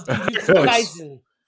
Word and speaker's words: Jujutsu Kaisen: And I will watch three episodes of Jujutsu Kaisen. Am I saying that correctly? – Close. That's close. Jujutsu Kaisen.